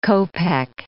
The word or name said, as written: kopek